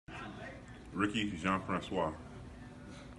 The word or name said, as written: Jean-Francois